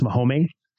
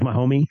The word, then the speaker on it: Mahome: It's actually pronounced Mahome. So it's like Mahome.